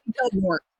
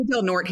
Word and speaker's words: Del Norte: No, it's Del Norte. They say Del Norte here. They don't even pronounce it Del Norte.